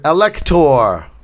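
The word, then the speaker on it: alektor: Because it comes from the Greek word elector for rooster. – Ah. Elector spelled with an A. – Yeah. – Elector. elector. Ah. Yes. – Long elector. A. Ah. – A not I. O not ow.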